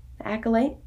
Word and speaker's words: The Acolyte: The Acolyte? – Yeah.